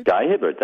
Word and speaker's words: Guy Hibbert: Now the writer, I'm embarrassed to say I don't know how to pronounce his name. It's Guy Hibbert, is that right? – Guy Hibbert, that's it.